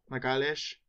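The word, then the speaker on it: Magalhães: Magalhaes.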